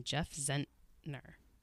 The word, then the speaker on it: Zentner: This month, we're going to be discussing The Serpent King, which is the first novel from Jeff Zentner. Is that how you say his name? – Yes.